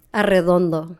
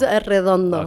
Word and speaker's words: Arredondo: Arredondo. – Arredondo. Oh, okay. – That's a tongue twister. – Yeah, Arredondo. – Yeah, it's Arredondo. – Okay, you got to roll your R's.